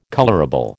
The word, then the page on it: colourable: American English